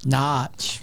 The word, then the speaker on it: Norwich: Leads come from behind on Norwich, or pronounced how? – Notch. – Very good.